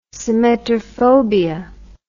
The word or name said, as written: symmetrophobia